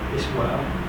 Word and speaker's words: espoir: Noun, espoir. E-S-P-O-I-R. – Espoir. – I said it right.